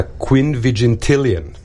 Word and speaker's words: quinvigintillion: The estimated number of atoms in the observable universe is pronounced a quinvigintillion, and it's a 3 followed by 78 zeros. – I think these people who are discovering these numbers are just having fun with us. A quinvigintillion.